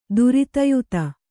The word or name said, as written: ಧೃತಿಯುತ